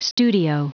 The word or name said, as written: studio